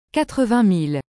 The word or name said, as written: 80000